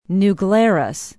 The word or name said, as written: new glarus